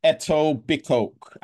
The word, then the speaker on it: Etobicoke: And Jax, I'm going to butcher this place, but it is pronounced as, gosh, Etobicoke, and this is in Canada.